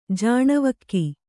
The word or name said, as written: ಜಾಣ್ವಕ್ಕಿ